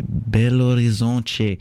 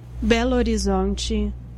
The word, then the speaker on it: Belo Horizonte: Yes, I did my research this time to pronounce it properly, and in Portuguese you would say Belo Horizonte. The internet was okay. This is how you're supposed to pronounce it. This is how a Brazilian person does. – Belo Horizonte.